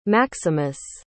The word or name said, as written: maximus